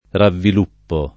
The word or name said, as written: ravviluppare